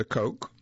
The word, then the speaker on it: Koch: The Koch, it's K-O-C-H, but pronounced Koch, brothers plan to pump even more cash into the 2016 presidential campaign.